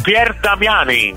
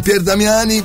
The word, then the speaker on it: Pier Damiani: San Pier Damiani. – Ecco, San Pier Damiani, bene, anche il cognome, ma non si dice altro.